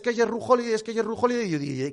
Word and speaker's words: Jrue Holiday: Me gusta cuando pronuncias Jrue Holiday. ¿Te importaría volver a hacerlo? Gracias, Jrue Holiday. Que sepas que yo no tenía ni idea de cómo se pronunciaba.